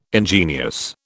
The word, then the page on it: ingenious: British English